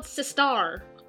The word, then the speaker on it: Sistar: Is it Sistar or is it Sister? Because it's spelled Sistar. – Sistar, because their band, their network, their what? Their company is called Star Something Entertainment, right?